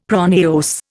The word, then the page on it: pronaos: Indian